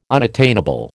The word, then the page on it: unattainable: American English